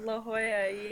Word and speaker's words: La Jolla: She's like, no, that's not how you say it. – Jewel, La Jolla, yeah.